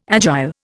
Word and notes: agile: Indian

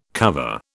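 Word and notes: cuvée: British English

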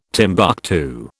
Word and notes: Timbuctoo: British English

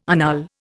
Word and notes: annul: Indian

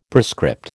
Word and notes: prescript: American English

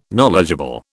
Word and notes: knowledgeable: British English

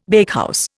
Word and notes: bakehouse: Indian

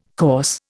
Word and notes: course: Indian

